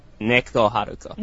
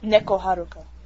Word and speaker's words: Neko Haruko: So yeah, that question was from Nico Haruko. I'd like to thank Neko Haruko. – Neko Haruko. – Yeah.